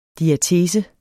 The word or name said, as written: diatese